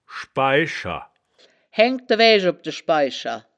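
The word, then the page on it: schbai-scher: Wittlich dialect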